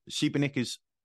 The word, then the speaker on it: Šibenik: Just again, to be kind of practical for a minute, tell us a little bit about how I might get to Šibenik. There's obviously accents on it, folks, but if you're going to Google it, it's Sibenik, I believe.